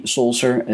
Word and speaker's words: Solcer: It's the C Solser.